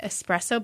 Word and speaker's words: espresso: And so on Friday, they're doing a evening of curated espresso beverages. And did you know? – Mm-hmm. – Okay, how do you say espresso? – Espresso. – Yes. – There's no X.